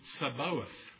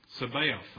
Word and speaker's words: Sabaoth: Sabaoth. Sabaoth, I should say. Let me say that correctly.